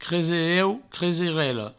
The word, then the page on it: cresereu: creserela; creserello